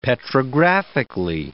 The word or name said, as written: petrographically